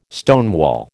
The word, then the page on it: stonewall: American English